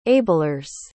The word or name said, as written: ablers